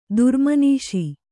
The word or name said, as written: ದುರ್ಮನೀಷಿ